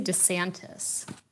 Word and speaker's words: DeSantis: How do you pronounce the Florida governor's name? – I say DeSantis.